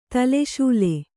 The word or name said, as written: ತಲೆಶೂಲೆ